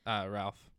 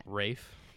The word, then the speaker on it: Ralph: And Ralph Fiennes is Ralph. – No, that's not how you say it though.